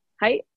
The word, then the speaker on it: hai: Hi. Like, it's spelled H-A-I. Hi. – Almost like hey.